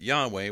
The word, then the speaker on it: Yahweh: So that there's a good reason to believe that Yahweh was close to the original pronunciation of the name.